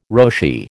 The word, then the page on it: Roshi: American English